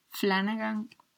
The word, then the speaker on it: Flanagan: Flanagan.